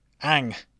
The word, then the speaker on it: Aang: Oh no, Aang, I pronounce it like M. Night Shyamalan did.